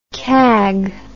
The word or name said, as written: cag